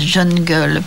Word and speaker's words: jungle: Attendez, ça n'est pas digne de la République que ces gens, une fois qu'ils sont là, soit dans cette la jungle, la jungle, faut paraît-il prononcer à l'anglaise jungle, mais c'est pire.